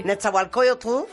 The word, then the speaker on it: Nezahualcóyotl: Nezahualcóyotl. ¿Lo dije bien? – Sí. – Nezahualcóyotl, con un concierto espectacular presentando el disco Estirpe con la Orquesta Sinfónica de Minería.